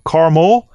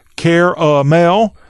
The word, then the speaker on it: caramel: Is it C-A-R-M-U-H-L, caramel? Is it caramel? I use both interchangeably, or I use both interchangeably, but they have different meanings. All right. Truthfully, I don't pronounce this caramel. I pronounce it caramel.